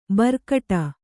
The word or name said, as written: ಭ್ರುಕುಟಿ